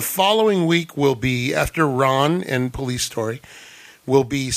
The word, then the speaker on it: Ran: It's called Ron, R-A-N, Ron. And then that's our cinema from Japan. And from Hong Kong, we'll be watching the early 80s classic Police Story. Police Story. – Ooh. How do you spell that last one? – Police Story. – No, Ron? – Ron. R-A-N as in nickel. – Ran. – It's Ran, but in Japanese.